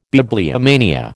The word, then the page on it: bibliomania: American English